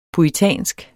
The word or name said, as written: puritansk